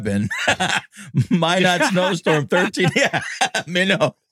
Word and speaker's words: Minot: You know, it's the States when it's phonetically, when you would pronounce it phonetically, you know, Minot. M-I-N-O-T, Minot. Yep. We're not as cool as they are in Canada. We're not doing any French accents. It's just Minot.